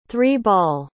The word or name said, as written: three-ball